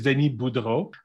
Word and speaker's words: Denis Boudreau: So my name is Denis Boudreau.